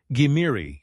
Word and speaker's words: Gimiri: The Behistun Rock describes him as the king of the Scythians, Saka or Cimmerians, pronounced Gimiri in Babylonian.